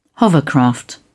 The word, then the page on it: hovercraft: British English